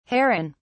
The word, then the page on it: heron(s): British English